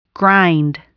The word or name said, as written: grind